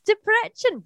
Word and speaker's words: Debrecen: So I will try my Debrecen. Debrecen. – Yes, Debrecen. Well done.